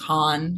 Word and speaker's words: Han: My Instagram handle comes from, it used to just be my name, but people see Han and they think Han because Han Solo. And so, yeah, it's a whole thing. But my Instagram handle is rhymes with man, rhymes period with period man.